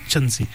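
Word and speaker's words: chintzy: Something that is very cheap, something that is very tacky, something that is of very poor quality is referred to as chanzi.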